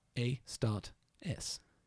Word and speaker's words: Astartes: A-S-T-A-R-T-E-S. A-start-S. Yes. – There we go. – Yeah. – So A-start S.